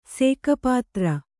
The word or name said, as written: ಸೇಕಪಾತ್ರ